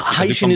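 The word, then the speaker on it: Hycean: Before we get started, I need some help with the pronunciation. Many people are calling, so it's hydrogen ocean worlds. And so, you know, I'm an English, you know, native English speaker. Uh-huh. For me, I would pronounce that Haitian world. But then when you say it's hydrogen and ocean, mm-hmm. then you say Haitian. – Yes, you're in the field. I've also been told that Haitian is the right pronunciation. – Yes, Haitian is the correct pronunciation.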